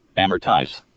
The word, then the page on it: amortize: American English